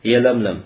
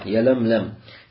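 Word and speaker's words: Ялямлям (يلملم): Говорит, пишется с буквой Я. Говорит, фаламун фамим, фаламун ухра, бада мимун ухра. То есть затем лям, потом мим, потом еще одна лям, потом еще один мим. Ялямлям.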